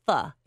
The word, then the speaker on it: pho: And by the way, it is pronounced pho. It is not pho.